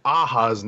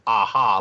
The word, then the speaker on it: Aja: So I don't know if you've heard any other podcasts that have talked about Hawkeye's first three episodes, but I have heard David Aja's name pronounced as Aha, like the band Aha, like Take On Me. – Okay. – So we've been erring on the side of caution and saying Aja, but I guess it is Aha.